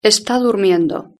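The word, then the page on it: esta durmiendo: Spain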